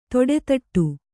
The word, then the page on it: ತೊಡೆದಟ್ಟು: toḍedaṭṭu